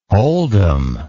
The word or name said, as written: holdem